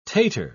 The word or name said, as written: tater